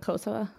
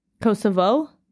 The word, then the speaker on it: Kosovo: Americans pronounce it Kosovo. Kosovo? Yeah. – Ah, yeah. Okay, that's just a Russian like linguistic thing where like any unstressed O is pronounced as an uh.